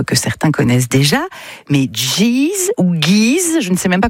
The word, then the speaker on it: Geese: Plus, je pense que c'est Geese, ouais. – Ouais, Geese. – Je dirais. – Ouais, parce que Geese, ça fait bizarre.